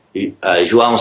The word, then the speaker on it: João: Which is kind of cool because I didn't know that before I made my email address, João Souza Rocks. – I like that. – Huh. You're the second person I've known that as J-O-A-O. You pronounce that João? – João. Like J-Wow almost.